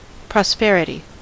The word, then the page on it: prosperity: /ˈpɹɒs.pɛ.ɹɪt.iː/